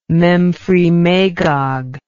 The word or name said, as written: memphremagog